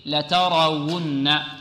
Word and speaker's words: لترون: علم اليقين، لترون، الواو هنا مضمومة، والحقيقة الواو عندما يكون عليها ضم والياء عندما يكون عليها كسر أو يكون تحتها كسر ففيها صعوبة، هناك صعوبة في نطقهما، فهنا لابد من إعمال المخرج ولابد من التركيز على الواو هنا، لترون لترون. بعض الطلاب ربما لا يحافظ على الضم جيد، يقول لترون لترون لترون أن أن لترون و و و لترون.